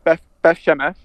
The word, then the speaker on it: Beth Shemesh: Beth Shem Shemesh. Sorry if I butchered that. – No, you didn't write it. Yeah, Beth Shemesh, yeah.